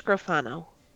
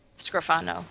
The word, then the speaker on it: Scrofano: This is going to be bad, but Melanie Scrofano? Scrofano? – I believe it's Scrofano. – Scrofano.